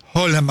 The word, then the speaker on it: Haaland: Es decir, Haaland, vamos a pronunciarlo como se pronuncia en Noruega, digamos, ahí la figura del arquero, pero hay toda una estructura de equipo fuerte, ¿eh?